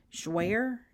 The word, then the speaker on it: Jouer: Okay, here's a question that asked me how I like the, I can't say it, Jouer? Is that how you pronounce it? J-O-U-E-R? – Yes.